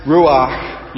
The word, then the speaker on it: ruach: To pronounce ruach, you must breathe out. And the word sounds like that which it describes, the sound of rushing wind.